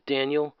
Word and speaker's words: Danuel: Um, yeah, Danuel House is his name, but his first name is spelled weird for some reason. It's still pronounced Daniel.